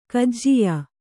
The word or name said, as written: ಕಜಿಯಾ